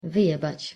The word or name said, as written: wyjebac